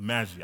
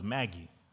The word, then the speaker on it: Magi: The Magi. I just found out how to say this word. I thought it was Maggie, but don't laugh.